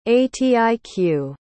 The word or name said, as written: atiq